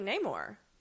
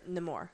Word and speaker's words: Namor: And it's the moment of her almost killing Namor. Or Namor, sorry. I keep going to the white people pronunciation.